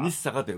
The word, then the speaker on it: 日坂: 掛川宿と金谷宿の間の29 番目が日坂、日坂と読むんですよ。